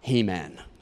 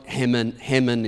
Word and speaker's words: Heman: And if you grew up in the 80s, your favorite author and mine is He-Man. So, or Heman is probably the correct way to pronounce it, but I like He-Man.